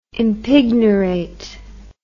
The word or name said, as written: impignorate